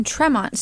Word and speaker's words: Tremont: You mean Tremont Street. – Tremont Street, yeah. – Did I say Tremont? Can you ask the question again? I was too fixated on your mispronunciation.